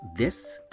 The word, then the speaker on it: です: Гласная «у» в слоге «су» связки «дес» не произносится, поэтому произносится просто «дес».